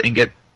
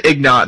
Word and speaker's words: Ingot: It's INGOT, but it's pronounced Ignite. Or Ignot. Kevin says Ignot, and that's completely incorrect.